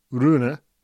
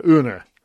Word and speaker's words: Rune: But it turns out that Holger Rune doesn't pronounce the R. So it's apparently Holger Une, which has really caused me some problems. So we're going to go with Holger Une.